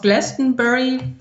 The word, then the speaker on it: Glastonbury: Danach geht es aufs Glastonbury Festival, was ich wahrscheinlich wieder falsch ausgesprochen habe.